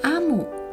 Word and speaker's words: 阿姆: 故事中的主角阿姆（O M U）念成阿姆，近似中文的阿姆。那在伊博语里面呢，是女王的意思。那伊博语是一种通行于西非国家奈及利亚的语言。好，我们开始喽。阿姆，谢谢你。